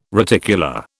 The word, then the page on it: reticula: British English